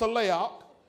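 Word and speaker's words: saleach (צלח): The word prosper comes from the Hebrew word saleach. That's how it's pronounced, saleach, which means to advance.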